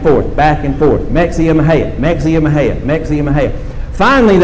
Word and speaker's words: Mexia: And the city is spelled M-E-X-I-A. And the guy driving says, oh, well, never heard of that. There's a city called Mexia. The passenger said, hey, you mispronounced that. It's pronounced Mahia.